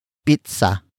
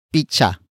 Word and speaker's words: pizza: Pizza. Pizza. Pizza is spelled as P-I-Z-Z-A. It is the Tagalog pronunciation for pizza, which is the direct object in focus. Some Tagalog speakers pronounce pizza as picha.